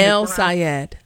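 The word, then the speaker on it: El-Sayed: Is it Saeed? Is that how you say his name? – El Sayed. Sayed. – El Sayed.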